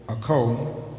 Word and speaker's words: Acho: In his book, O Sonny Okoh, the last name is spelled A-C-H-O, Okoh.